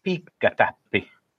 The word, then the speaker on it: piikkatäppi: So this show's word is piikkatäppi.